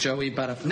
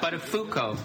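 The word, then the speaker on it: Buttafuoco: Matthew, I think you mispronounced that guy's name a few times. – What, it's Joey Butterfly? – No, it isn't, Matthew. It's, but a Fuko.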